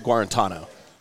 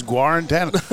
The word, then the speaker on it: Guarantano: Just like it's Garantino, isn't it, instead of Garantano. You know, how do you pronounce that? – I think it's I've always heard Jared Guarantano. – All right, it's Guarantano.